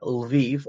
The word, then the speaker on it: Lviv: Or pointing out that some of Western Ukraine, like Lviv or Lvov, if you want to pronounce it that way, was part of Poland until the Soviet Union took it.